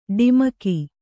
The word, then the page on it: ಡಿಮಕಿ: ḍimaki